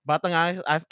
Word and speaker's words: Batang Angas: Batang Angas, I just realized I never spell it for you guys. It's BATANGANG. A-S. Batang Angas.